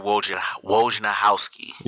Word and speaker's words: Wojnarowski: Adrian Wojnarowski.